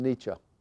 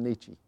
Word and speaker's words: Nietzsche: Some pronounce his name Nietzsche. I call him Nietzsche.